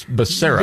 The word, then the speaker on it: Becerra: Baccaria, Baccaria, Baccaria. So I have to go, Becerra. And I have to tell myself like five times. – But it's not it's Becerra.